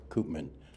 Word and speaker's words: Koopman: Koopman. That's spelled K-O-O-P as in Paul, M-A-N.